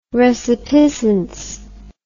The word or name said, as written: resipiscence